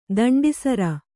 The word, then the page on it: ತುಂಡುಸೀರೆ: tuṇḍu sīre